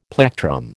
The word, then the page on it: plectrum: American English